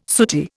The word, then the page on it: sooty: Indian